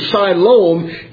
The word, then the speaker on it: Siloam: And that term Shiloh, that's the Hebrew interpretation. Siloam is the Greek interpretation. Or the Greek pronunciation, rather.